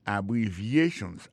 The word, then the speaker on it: abbreviations: Abbreviations, accent tonique là, tombé sous troisième syllabe là.